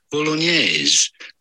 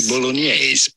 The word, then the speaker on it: bolognese: Of course, in English or American, you normally say it bolognese. Bolognese, but the typical Italian pronunciation is quite different. In fact, in Italian, they say bolognese. – Okay. They just pronounce it. – So more, yeah. – We're bolognese. – Yeah. So it's N-Y-A.